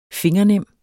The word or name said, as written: fingernem